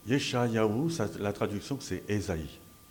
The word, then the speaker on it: Esaïe: En français, dans les traditions juives, catholiques, orthodoxe, on utilise la forme d'Isaïe, Isaïe, et pas Esaïe, Isaïe. Mais chez les protestants, on prononce et on écrit Esaïe.